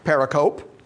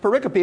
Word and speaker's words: pericope: It is not pronounced pericope. It's pronounced pericope.